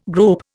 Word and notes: grope: Indian

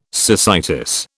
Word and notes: cecitis: British English

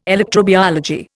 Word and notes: electrobiology: Indian

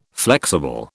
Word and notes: flexible: British English

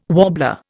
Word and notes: warbler: Indian

